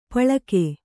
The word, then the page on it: ಪಳ್ಕೆ: paḷke